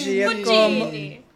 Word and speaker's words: Giacomo Puccini: So I'll try to pronounce Italian composer Giacomo Puccini. – Puccini. – Giacomo. Puccini.